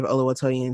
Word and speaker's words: Oluwatoyin: But I feel like uh, Oluwatoyin could have really taken off and you know 19, 19, years old that's she was a baby. a baby. um if you don't know the story of Oluwatoyin